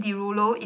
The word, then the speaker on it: Derulo: His stage name, Derulo, is a pronunciation respelling of his surname.